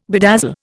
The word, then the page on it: bedazzle: Indian